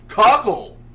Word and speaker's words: Cockle: Imagine Mr. Cockle coming his way. – It's spelled cock with an L-E at the end, so there's only one way to pronounce that.